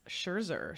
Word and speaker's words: Scherzer: and the Mets also signed Max Scherzer. So, and there, I finally say it right, just when he leaves.